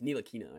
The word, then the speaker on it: Ntilikina: The T is just there in that name. I don't know what it's for. It's Ntilikina, I believe.